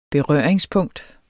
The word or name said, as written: berøringspunkt